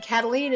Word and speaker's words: Kataline: Karen is spelled the normal way, no goofy Y's or Q's, K-A-R-E-N, and Kataline is spelled K-A-T-A-L-I-N-E.